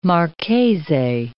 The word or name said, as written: marchese